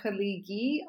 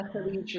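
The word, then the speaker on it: Khaleeji: Do I say it correctly too? There's no actual ka in the beginning, right? – Haliji, yeah, that's correct. Okay. In Egypt, people say Haligi often, but that's because they don't pronounce the J sound. They do hard Gs. But in the Haliji region, they say Haliji.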